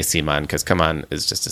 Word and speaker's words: CMON: Yeah, it's just C-M-O-N, all capitals. Simon sounds right. But on the Dice Tower News, I pronounce it the way that I think the way they want it to be pronounced. – Yeah. – Yeah. But this is my podcast and I can say Simon.